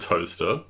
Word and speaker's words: Towcester: Because I went to a public school first in a town called Toaster, which was really great. It's spelled Towcester, like you know, like Leicester.